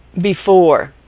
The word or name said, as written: before